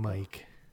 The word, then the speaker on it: Mike: And I'm Mike.